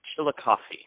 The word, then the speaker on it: Chillicothe: The name of the town is called Chillicothe.